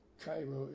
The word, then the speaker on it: Cairo: I think the correct Spanish pronunciation would be Mejia, but Mejia is the way they pronounce it and the way people in Cairo, Illinois pronounce their town Cairo anyway.